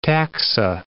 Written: taxa

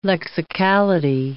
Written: lexicality

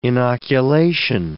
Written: inoculation

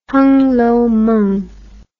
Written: hungloumeng

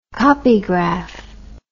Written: copygraph

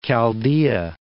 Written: Chaldea

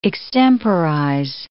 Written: extemporize

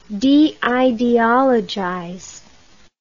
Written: deideologize

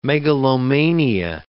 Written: megalomania

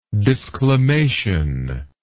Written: disclamatory

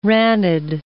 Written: ranid

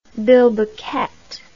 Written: bilboquet

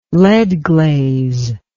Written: lead glaze

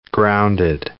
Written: grounded

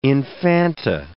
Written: infanta

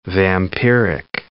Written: vampiric

